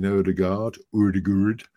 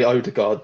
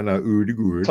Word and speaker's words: Ødegaard: We signed Martin Ødegaard, as I heard it is actually pronounced. – He lets us say Ødegaard though, so it's fine. – No, Ødegaard. – Told. He did say that we could if we wanted to.